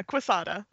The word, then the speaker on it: Quesada: Yes, but Joe, how is it? Quesada? Is that how you say it? – Yeah, Quesada.